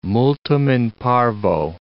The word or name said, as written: multum in parvo